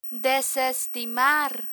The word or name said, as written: desestimar